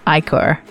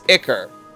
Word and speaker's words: ichor: Apparently, the American English pronunciation is Ichor, and the British English is Ichor. – Oh, so the proper way to say it is Icker. And then we here in the States go, yo, it's Icor.